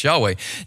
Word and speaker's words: Yahweh: and it's capital L and then lowercase caps O-R-D, then that's referring to God's personal name, Yod-Heh, Vav-Heh, we think it is pronounced Yahweh.